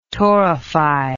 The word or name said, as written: torrefy